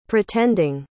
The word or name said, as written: pretending